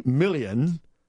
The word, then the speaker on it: Millian: And so Steele goes to these guys, and this one fellow in particular named Sergey Milian, I guess is how you pronounce his last name. – What a name. – M-I-L-L-I-A-N.